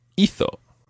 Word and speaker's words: hizo: And he, she, or it did is hizo. – Hizo. – Hizo. – Hizo. – And that's spelled H-I-Z-O.